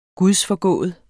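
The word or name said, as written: gudsforgående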